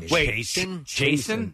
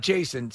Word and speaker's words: Chasen: Wait, Chasen? – Yeah. – Spell that. – Not Jason. C-H-A-S-E-N.